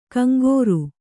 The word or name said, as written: ಕಂಕರೆ